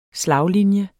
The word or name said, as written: slaglinje